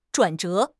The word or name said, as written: 转折